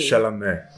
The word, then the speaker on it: Chalamet: Chalamet, Timothy Chalamet, Chalamet. – Chalamet.